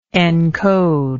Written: encodement

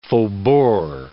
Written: faubourg

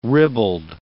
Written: ribald

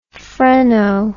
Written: phreno

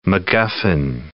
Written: MacGuffin

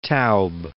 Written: Taube

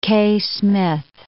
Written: Kaye-Smith

